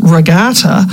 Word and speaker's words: Regatta: I know it's spelled Regatta, and perhaps the British say it that way, but we say Regatta in Newfoundland.